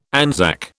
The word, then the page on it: Anzac: British English